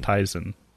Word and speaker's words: Tizen: It is Tizen. It's pronounced Tizen.